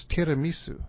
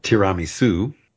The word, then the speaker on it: tiramisu: but it's tiramisu. – Right, and in the 80s, I think it was a big hit. You found it on every menu and often pronounced tiramisu. Tiramisu in Italian,